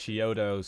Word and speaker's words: Chiodo: Chiodo spelled like Chiodos, but without like the, the S. Yeah. Like Cheetos.